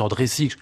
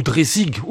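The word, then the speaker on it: Dreisig: Elsa qu'on peut prononcer en sig ou Dreissig aussi bien, je crois qu'en sig euh, on lui demandera la prochaine fois qu'elle viendra,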